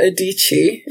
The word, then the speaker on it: Adichie: So I'm going to read a quote by Chimamanda Adichie.